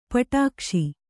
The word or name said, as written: ಪಿಡಿಕೈಸು